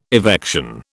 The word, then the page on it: evection: British English